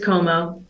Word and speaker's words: Comeaux: I mean, it's every other vowel than what you see. There's all the vowels in the world, but you pronounce it with a O. So it's just Como.